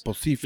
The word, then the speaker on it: Bocephus: Bocephus. You know, Hank Williams Jr., Bocephus. – Whatever you want to be called. Sexy man. I'll call you sexy man for now. But. – Sexy man Bocephus.